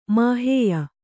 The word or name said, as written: ಮಾಹೇಯ